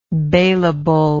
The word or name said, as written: bailable